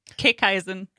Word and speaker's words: Kickeisen: It's pronounced Kickeisen. – Kickeisen. – Yep. – Kickeisen. – Yeah. Slightly German. – Kickeisen. – Correct.